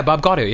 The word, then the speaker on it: Bob Gaudio: Is that how you say it? Bob Gaudio? – Yeah, Bob Gaudio, yeah.